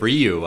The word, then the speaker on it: Ryu: Well, it's just like Ryu. I can't stop saying Ryu, but it... – No, Ryu's right. – I thought it was... – No, you kept saying Ryu.